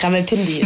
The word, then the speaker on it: Rawalpindi: Ähm, ja, war schon fast richtig. Rawalpindi ist, glaube ich, so ein bisschen schneller, ja. Ähm, okay. – Genau, Rawalpindi ist eigentlich so der chaotische Speckgürtel von Islamabad.